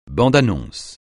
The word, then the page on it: bande-annonce: francais